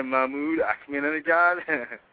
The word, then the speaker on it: Mahmoud Ahmadinejad: the Mahmoud Ahmadinejad.